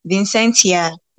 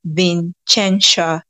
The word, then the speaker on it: Vincentia: My name is Vincentia Vin. Like, it has different pronunciation. Some people call me Vincentia, but the actual pronunciation I think is Vincentia.